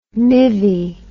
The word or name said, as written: mivvy